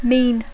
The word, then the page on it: mean: English